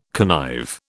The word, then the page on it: connive: British English